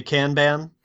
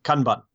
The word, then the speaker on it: Kanban: Well, if you're in America, you're likely to say Kanban, which is not wrong. The rest of the world, I think, taking their lead from British English, would say Kanban. And the proper Japanese pronunciation, I think, is Kanban. It's very staccato.